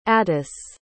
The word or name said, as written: attis